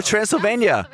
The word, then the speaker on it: Transylvania: No, Transylvania.